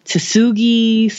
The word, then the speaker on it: Tsugi: Tsugi. It's T-S-U-G-I.